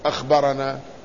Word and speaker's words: أخبرنا: وكذلك عندما يكتبون أخبرنا فيحذفون الهمزة، يحذفون الخاء والراء والباء، ويكتفون بالهمزة والنون والألف كأنها أنا، لكن لا تنطق أنا وإنما تنطق أخبرنا.